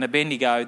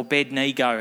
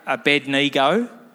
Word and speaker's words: Abednego: Now, it's interesting, Andy spoke last week about Shadrach, Meshach and Abednego. They were part of, or Abednego, Andy. Abednego. What, that's, you've pronounced it wrong last week, buddy. You got it wrong.